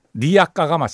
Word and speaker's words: 리아카: 사실 리어카도 아니고 예. – 리아카가 맞습니다. 리아카. 예. 일본 발음.